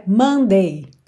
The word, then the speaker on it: Monday: Sendo que a sílaba tônica tem que ser no começo, não Monday, mas sim Monday, Monday, ok? Mandei bem. Alright? É bem parecido esse som. Mon, mon, tá? Ele é bem nasal.